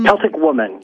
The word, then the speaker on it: Celtic Woman: And Celtic Women, am I pronouncing that right? There's some Celtic Woman, yep.